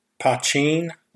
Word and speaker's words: poteen: It's billed as a civilized poteen, which is what I am told is the pronunciation for that word.